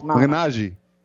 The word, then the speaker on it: Malignaggi: Malignaggi. – You got it close. Yeah, close. – Malignaggi. – There you go. – The G at the end. G-E. – Italians pronounce the G differently. Yes.